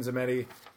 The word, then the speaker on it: Tzameti: It's called 13 Zemedi. It's spelled T-Z-A-M-E-T-I.